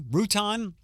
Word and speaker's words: Routon: Grace, R-O-U-T-O-N. Roughton, Rutan.